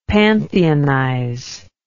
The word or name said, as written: pantheonize